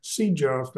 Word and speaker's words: Ceja: Is my understanding correct that Officer Sejaf, that's how his name is pronounced, C-E-J-A,